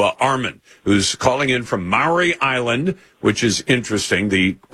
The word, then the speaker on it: Maury: It's pronounced Maury Island. – Oh, Maury Island. That, you're right. Thank you for the correction.